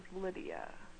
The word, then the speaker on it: Lydia: But as I got to high school, people started to realize that the only word that rhymes with Lydia is, you guessed it, chlamydia.